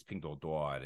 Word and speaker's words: Pinduoduo: you know, and then later on, you know, you had Xiaomi and you had uh, Huawei, and then of course now it's it's Pinduoduo, it's uh, Xiaohongshu, and it just like Meituan, yeah, crazy, right? – Your, so, your Chinese pronunciation yeah. is perfect for these. Companies.